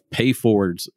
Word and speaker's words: PayFWDs: I'm the owner of Goodson Clothing and Supply, and I have with me Eric Monks, the president of PayFWDs.